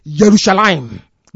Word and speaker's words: Yerushalayim: There is no J in Hebrew pronunciation. It is Y. The same way that Jerusalem is not Jerusalem. It is Yerushalayim, not Jerusalem. There's nothing like J in Hebrew.